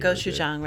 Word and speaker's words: gochujang: It's going to be gochujang, right? – I'm so proud of you right now. Oh, you said it right.